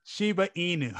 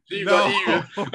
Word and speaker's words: Shiba Inu: I have Shibu, a lot of Shibu. – Shiba Inu. – That's it. Shiba Inu.